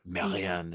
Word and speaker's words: Marianne: it's a French series called Marianne is how you say it if you're super frou-frou like me,